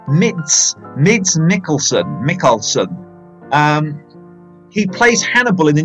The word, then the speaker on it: Mads Mikkelsen: Okay, movie tip, definitely Mid Mackelson. Have I got his name right there? You have to help me out there. Mids Mackelson Mickelson.